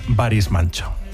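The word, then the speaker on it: Barış Manço: es Baris Manço.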